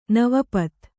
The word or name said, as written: ನವಪತ್